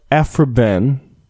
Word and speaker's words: Aphra Behn: So today we are talking about Aphra Behn.